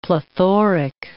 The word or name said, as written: plethoric